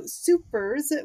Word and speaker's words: SuperS: But yes, so Supers, which is spelled with two capital S's, one at the end and one at the beginning. A lot of people call it Super S for that reason. Perfectly valid. It looks like that's how it's spelled or pronounced.